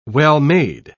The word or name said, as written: well-made